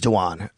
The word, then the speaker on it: Dwan: And just so everybody knows, I know it's spelled like Dwayne, it is Dwan. I checked in on that. Just it is Dwan Smoot, and he is also playing possibly.